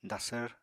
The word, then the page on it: dasvr: apatani